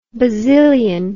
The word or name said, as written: bazillion